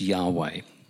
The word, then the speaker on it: Yahweh: So when God reveals himself as I am who I am, that gets transliterated into the letters YHWH, and we pronounce that as Yahweh.